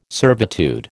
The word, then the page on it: servitude: American English